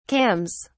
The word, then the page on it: cams: British English